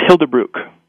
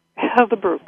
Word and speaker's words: Hilde Bruch: And did I say her name right? – It's Hilde Bruch. – Hilde Bruch. Well, I was close.